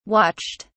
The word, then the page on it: watched: British English